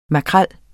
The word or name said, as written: Scomber scombrus